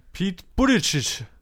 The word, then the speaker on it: Buttigieg: Hm, Pete Buttigieg.